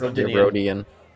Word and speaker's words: Rodian: It's not just a random Rodian, however you pronounce it. – Rodian. – Yes. Rodian.